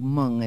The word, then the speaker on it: Mwng: they did a sort of all Welsh language album called Mung, and it's spelt M-W-N-G,